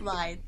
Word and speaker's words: lithe: He slipped around her Lilith body. – Nope. yeah, that's the wrong word. – That's Ilf? Not how you say it. It's lithe.